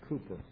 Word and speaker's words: Cowper: Listen to this song that was written by a man named Cooper, or Cowper, C-O-W-P-E-R, it's actually pronounced Cooper.